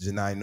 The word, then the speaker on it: Janai: Shout out to Janae Norman, Janai Norman, I believe is how you say her name. Janai. Uh, ABC News announced Janai Norman as the new co-anchor for Good Morning America Saturday and Sunday broadcast.